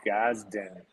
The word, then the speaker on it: Gadsden: I'm in kind of like in a limbo here, but it's called Gazden. – Gazden? – Yeah. – How do I spell that? – Gadsden. – It's in Alabama.